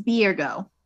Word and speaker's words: Byregot: And if you want to look him up, it's Byregot. – Not Byregot? – It's actually pronounced Birgo.